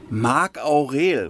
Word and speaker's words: Marc Aurel: Marc Aurel, oder? – Ja, ich wollte jetzt nicht damit rumprotzen.